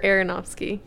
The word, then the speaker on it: Aronofsky: Darren Afronsky? – Aronofsky? Or Aronofsky.